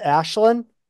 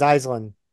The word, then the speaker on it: Aislinn: So I'm learning that name is actually, the actual Irish pronunciation is Ashlyn. – Oh, what I said, Aslan. – Well, the Americanized pronunciation is Aislinn.